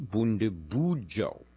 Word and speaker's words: Bundibugyo: But instead of referring to the cause of the outbreak in Africa as the Ebola virus, he suggests calling it the Bundibugyo virus. It's actually pronounced Bundibugyo.